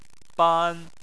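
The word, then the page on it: ban: tone 1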